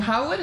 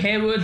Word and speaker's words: Harewood: Is it Harwood? Is that how you say it? Or Harewood? – Harewood.